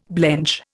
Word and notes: blench: Indian

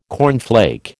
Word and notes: cornflake: American English

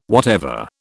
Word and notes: whatever: British English

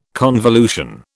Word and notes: convolution: British English

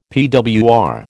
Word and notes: PWR: American English

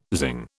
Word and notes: zing: British English

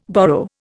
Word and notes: borrow: Indian

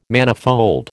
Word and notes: manifold: American English

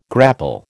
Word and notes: grapple: American English

